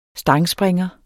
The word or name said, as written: STANGSPRINGEREN